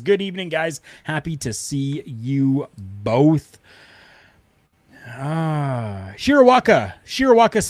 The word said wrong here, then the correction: Shirakawa: Special. Shirakawa. Yes, I think I pronounced that properly.